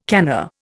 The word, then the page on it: canna: Indian